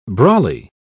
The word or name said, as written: brolly